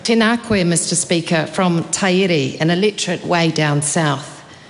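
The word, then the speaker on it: Taieri: Which is Tyree. Is that right? – Ah, yes.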